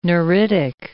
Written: neuritic

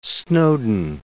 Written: Snowden

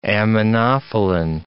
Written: aminophylline

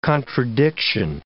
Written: contradiction

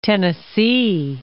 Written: Tennessee

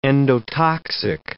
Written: endotoxic

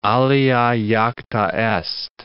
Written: alea jacta est

